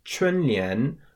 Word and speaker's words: 春联: So another thing that happens during this period of 大扫除 is people hang up these things called 春联 or spring couplets. So that's spelled C-H-U-N in the first tone, 春, and then L-I-A-N in the second tone, 年, literally meaning spring and couplet.